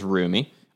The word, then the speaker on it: Roumie: I looked up how to pronounce the last name. It is Roumie, R-O-U-M-I-E, unless the website I saw it was wrong.